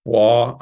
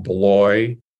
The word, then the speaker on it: Bloy: B-L-O-Y. – B-L-O-Y. Okay. – Now, why is pronounced Bois? I have no idea. So it's not Blois. – Okay. – Another figure that impacted Bernanosc was Charles Peguy, P-E-G-U-Y.